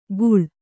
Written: ಖೂಳ

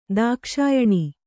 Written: ದಾಕ್ಷಾಯಣಿ

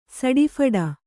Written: ಸಡಿಫಡ

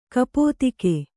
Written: ಗುಪ್ತಕ

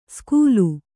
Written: ಸ್ಕೂಲು

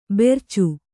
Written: ಭರ್ಚಿ

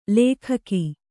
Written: ಲೇಖಕಿ

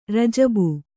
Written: ರಜಬ

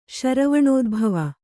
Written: ಶರವಣೋದ್ಭವ

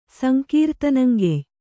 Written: ಸಂಕೀರ್ತನಂಗೆಯ್

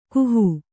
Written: ಕಾಹಿ

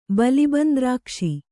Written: ಬಲಿಬಂದ್ರಾಕ್ಷಿ

